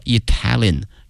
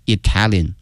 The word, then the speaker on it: Italian: 意大利这个词啊，同学们要小心了，它的名词和它的形容词呢，这个发音同学们一定要小心了。意大利呢是 Italy 啊，国名。如果说意大利的或意大利人叫 Italian，哎，所以说这个发音还是有区别的。 Italy, Italian.